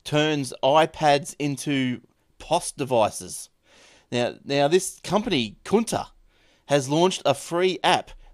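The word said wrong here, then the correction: Kounta: Anyway, the counter. Counter. – Hey? Counter. Is that counter. How you say it? – You know, like you have your cash register up on the counter.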